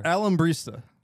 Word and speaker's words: Alambrista: Alambrista. How would you say that? Like, how would, like, the... – Alambrista? – Alambrista? – Yeah, that's right. – I'm hitting it too hard is what it is.